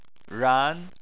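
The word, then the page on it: ran: tone 2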